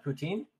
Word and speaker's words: poutine: Poutine? – Yes, yes.